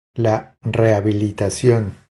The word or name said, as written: La rehabilitacion